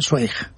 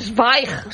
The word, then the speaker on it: Zweig: Tú sabes que yo toda mi vida he estado diciendo que yo leía Stephen Zweig. – Ah, claro. Es no. Zweig.